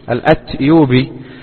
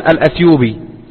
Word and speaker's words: الأتيوبي: ومن طرائف ما يروى أن شيخنا الشيخ العلامة محمد بن علي آدم الأتيوبي الأتيوبي يكتب في كتاباته وفي كتبه بالتاء، بينما السائد في النطق أن الناس يقولون الأثيوبي له ولغيره. فحصل